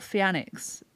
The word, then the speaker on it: Phianixx: It's spelt Fianix. People call me Fianix, Fianix, Fi.